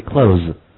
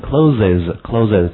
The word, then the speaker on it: clothes: 好，需要说明一下的是衣服这个单词，我们再读一下，不要费 clothes, clothes. 好，那么这关于这个词呢，大家没有必要读成 closes 这样是不对的，或者是你如果能够按照这个音标能把它划过去是最好的，你比如说你能读成 close 这样是最好的，但如果你实在读不过去你就 close 就没问题。